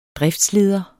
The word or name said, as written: driftsleder